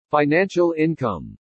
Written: FINANCIAL INCOME

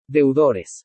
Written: DEUDORES

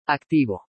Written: ACTIVO

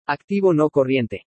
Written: ACTIVO NO CORRIENTE

